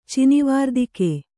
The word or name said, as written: ಚಿನಿವಾರ್ದಿಕೆ